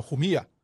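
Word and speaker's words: Jumilla: 对对对，因为这个事情其实已经过去十年了，我们是 2016年，十年之前去到了西班牙一个鸟不拉屎的小村落，叫做胡米亚。那胡米亚呢是它的这个西班牙文的读音，那它的中文译名叫胡米利亚。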